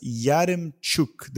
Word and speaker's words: Yaremchuk: Benfica's Yaremchuk. Did I say it right, Adrian? Because this one's yours. – You got it.